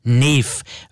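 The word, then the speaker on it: Knef: Dort hieß sie nicht Kniff, sondern Neff, weil die Amerikaner das K nicht aussprechen können.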